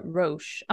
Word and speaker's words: Roche: But the last people that we go and speak to in Novigrad is Roche. Do I say that right? – Boo. That's how I say it. – It is.